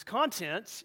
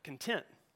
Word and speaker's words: content: How many of you wrote it down this way, where you emphasize the second syllable? That's a verb or an adjective, content. Those two words, to some degree, are far apart. One means stuff, the other means that you're at peace, at some level, at some degree of peace.